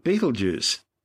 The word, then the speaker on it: Betelgeuse: Well, you may have heard it described by a different name, Betelgeuse. But both Betelgeuse and Betelgeuse are simply tortured mispronunciations of its original Arabic name, Ibtal Yaza, meaning the hand of the big man.